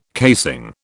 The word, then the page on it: casing: British English